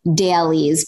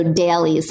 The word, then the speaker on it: Daleez: It's called dailies, but it's spelled D-A-L-E-E-Z. So dailies. Sometimes I Frenchify it and I say dailies, but dailies.